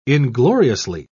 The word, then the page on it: ingloriously: British English